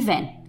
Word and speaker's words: Van: Mackenzie e Van. E se escreve V-A-N, como se fosse van, mas em inglês, com o sotaque, fica van.